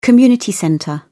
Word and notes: community centre: British English

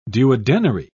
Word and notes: duodenary: British English

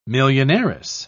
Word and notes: millionairess: British English

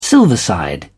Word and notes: silverside: British English

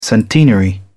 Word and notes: centenary: British English

